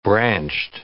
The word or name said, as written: branched